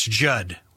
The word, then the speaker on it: Judd: That's Judd. With two D's, the second D is silent.